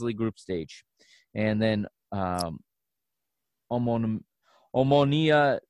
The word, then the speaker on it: Omonia: Omnia Nicosia, is that right? – Yeah. Omonia.